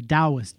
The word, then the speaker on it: Taoist: Spelled with a T, pronounced with a D, a Taoist, D-A-O-T-A-O-I-S-M.